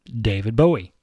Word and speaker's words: David Bowie: In the 1960s, a young singer from England named David Jones took Jim Bowie's last name for his stage identity, although he pronounced it in the English manner and became David Bowie.